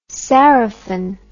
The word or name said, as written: sarafan